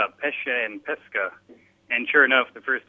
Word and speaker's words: pesca: Pesce and pesca. Pesce as in it's fish, and pesca would be peach.